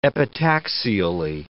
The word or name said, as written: epitaxially